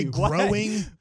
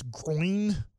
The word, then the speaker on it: groin: They always say growing, but it's groin if I'm trying to say it right.